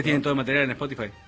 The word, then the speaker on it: Spotify: Spotify. Muy bien esa pronunciación. Spotify. – Pero nosotros le clavamos Spotify. – Claro, Spotify, con ese bien fuerte, como corresponde.